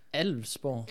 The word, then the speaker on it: Alvsborg: Men det er jo så i, øh, øh mod et, ja, en borg, der hedder Avlsborg, tror jeg nok, det udtales. Eller, øh, skal de se, hvordan det er. Øh, A-L-V-S-borg. Alvsborg. Ja, Alvsborg. Ja, det lyder meget rigtigt.